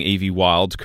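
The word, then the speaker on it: Evie Wyld: I think I am pronouncing Evie Wild correctly. – But yep, that's it.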